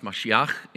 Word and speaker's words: Mashiach: And the word he uses here in Hebrew is pronounced Mashiach.